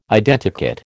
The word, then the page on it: Identikit: American English